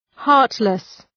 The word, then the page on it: heartless: ‘hɑ:rtlıs